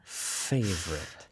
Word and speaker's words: favorite: F 발음은 바로 저 소리입니다. – Favorite. – Favorite이죠. 그래서 바람을 내뿜는 거를 익숙해지셔야 돼요. 우리말에는 사실 이 바람을 내뿜는 소리는 잘 없거든요. 그래서 favorite food. 거기에 또 V 발음까지 있네요. Favorite에. 근데 이 입의 모양은 똑같지만 V는 바람이 아니라 V 소리를 내는 거고요.